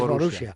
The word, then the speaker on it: Borussia: No, la pronunciación Borussia es Borussia. Borussia. Hay dos.